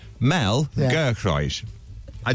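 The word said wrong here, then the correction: Giedroyc: Gedroich. – Gedroich.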